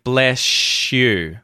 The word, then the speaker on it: bless you: So, you'll hear too how we say bless and you, and the yod, the y sound at the front of the word you, blends with the s sound at the end of the word bless, and you end up with bless you. So, it sounds like an sh sh sound, bless you, bless you, bless you, instead of bless you, bless you.